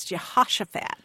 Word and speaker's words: Jehoshaphat: But both of those pronunciations, if you go back to the original Hebrew, are wrong. It's Jehoshaphat.